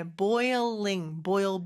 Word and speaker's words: boiling: 자, 아까 Boggling. – 보글링이라고 했는데, 네, boiling, boil, boil, boil, boil. 이게 영어식 보글보글, 보글보글입니다. 뭐, 비슷하면서 약간 다르죠, 그죠? 자, boiling, boil.